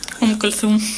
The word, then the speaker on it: Umm Kulthum: escucharemos a Umm Kulthum. Seguro que lo digo mal, a ver si lo puede decir alguien mejor que yo. – Umm Kulthum.